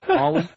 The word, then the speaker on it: Ali: Wait, is it Ollie, like, O-L-L-I-E? Like, no. – You pronounce it like that? No. I know it's A-L-I. You pronounce it like Ollie? – Well, it's like Muhammad Ollie, man.